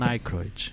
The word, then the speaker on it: Aykroyd: E o Dan Aykroyd, como é que fala? – Dan Aykroyd.